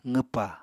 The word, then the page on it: qwpa: apatani